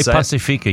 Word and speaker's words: Pacifica: Moana Pacifica, I think is how you say it. You say Pacifica, yeah.